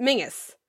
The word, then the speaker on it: Menzies: Apparently, Tobias Menzies, when he was coming up, it's pronounced Mingus, but it's spelled Menzies. – Okay. – And so... – Okay. – So, but he just goes, with Menzies now, because that's what it looks like.